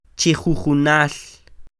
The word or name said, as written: Chi jujunal